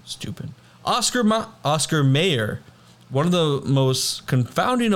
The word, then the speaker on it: Mayer: But it's pronounced Mayer, which makes you think it's an E.